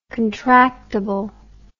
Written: contractible

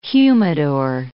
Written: humidor

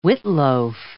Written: witloof

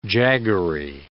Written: jaggery

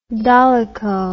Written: dolicho

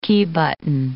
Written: key button

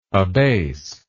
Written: abaser

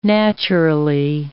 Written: naturally

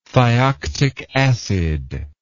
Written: thioctic acid

